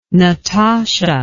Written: Natasha

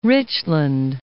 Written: North Richland Hills

